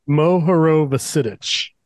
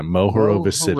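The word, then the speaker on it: Mohorovičić: Mohorovicic. – Yeah, Mohorovicic.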